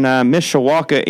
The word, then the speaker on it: Mishawaka: How's things over in Mishawaka, Indiana, correct? – That's correct, and you pronounced it well. Well done.